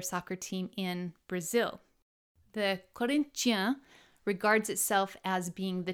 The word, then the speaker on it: Corinthians: And you would never be able to tell it from the pronunciation, but Corinthians is Corinthians. They're named after Corinthians, like in the New Testament.